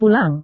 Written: pulang

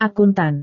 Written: akuntan

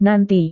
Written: nanti

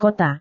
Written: kota

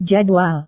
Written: jadwal

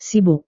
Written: sibuk

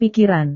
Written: pikiran

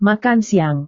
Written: makan siang

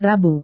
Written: rabu

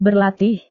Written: berlatih

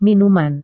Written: minuman

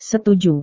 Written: setuju

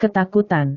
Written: ketakutan

